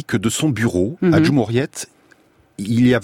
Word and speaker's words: Cumhuriyet: Je ne sais pas si je le prononce. – Cumhuriyet, voilà, qui est un très grand quotidien turc, quotidien de référence, extrêmement critique à l'égard du pouvoir.